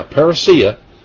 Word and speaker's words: parousia: This is the coming, parousia, or parousia is probably the best way, I think, parousia is the proper pronunciation of this one.